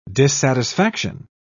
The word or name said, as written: dissatisfaction